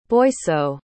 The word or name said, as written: boisseau